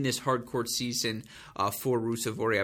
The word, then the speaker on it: Ruusuvuori: Emil Ruusuvuori, or Emil Ruusuvuori, as I learned how to properly pronounce.